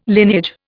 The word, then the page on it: lineage: Indian